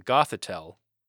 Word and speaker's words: Gothitelle: And what we would know this to be is a Gothitelle. Did I pronounce that right? – Yes.